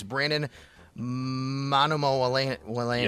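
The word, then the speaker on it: Brandon Manumaleuna: No, but you're kind of close, actually. It's Brandon Manumo Wallena.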